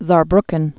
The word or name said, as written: Saarbrucken